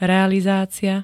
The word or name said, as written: realizácia